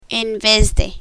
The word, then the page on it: en vez de: spanish